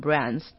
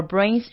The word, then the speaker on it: brains: 然后那个 brain 的话，它部分 AI 的部分是长 AE 的音，然后还有 B 音，就是那个因为有个 N，brains，不要念成 brands。如果是这样 brand 的话，就是变成另外一个单字，BRAN 这样子，所以是 brains，A 音要很明显。然后 our brains share six of the same chemicals， six of the same chemicals， same chemicals 的。